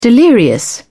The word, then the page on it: delirious: British English